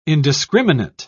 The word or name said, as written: indiscriminate